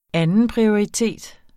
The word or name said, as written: andenprioritet